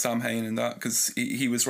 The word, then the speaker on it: Samhain: And it's not pronounced Samhain as it was in that show that got cancelled on Netflix recently, Sabrina, the remake.